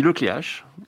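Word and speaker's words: Le Cléac'h: En fonction des régions, on va prononcer le CH soit R, soit K, soit CH, donc moi c'est, on dit le Cléache.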